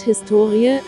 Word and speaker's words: Historie: Das Wort Historie, HS2IE, die Betonung liegt auf dem O, bezeichnet bis in das 18.